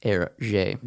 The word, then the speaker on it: Hergé: He created the pseudonym for Hergé by flipping his initials and then using the French pronunciation for them. So instead of G-R, he flipped him to R-G.